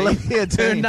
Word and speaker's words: Olivia Dean: Good luck for that draw, the Olivia Jean draw, has been said. Why can I not say Olivia Dean.